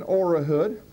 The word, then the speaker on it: Orahood: My name is Brian Orahood, and I'm responsible not only for... Oh, you need me to spell that? It's O-R-A-H-O-O-D.